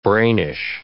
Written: brainish

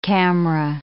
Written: camera

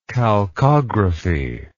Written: chalcography

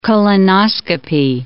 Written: colonoscopy

